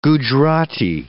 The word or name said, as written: Gujrati